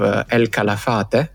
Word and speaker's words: El Calafate: El Calafate. Is right the pronunciation, Daniel? – Pretty much, very close.